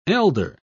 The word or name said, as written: elder